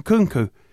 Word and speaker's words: Nkunku: Nkunku.